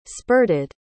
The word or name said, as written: spurted